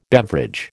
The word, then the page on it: beverage: American English